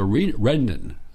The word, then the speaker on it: Rendon: The Democrat Speaker, Rendon, that's the right pronunciation, refused to do that.